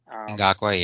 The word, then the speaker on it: Ngakoue: Yannick Ngakoue, or however the hell you pronounce it. – Ngakoue, yeah.